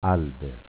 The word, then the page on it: Alber: dialetto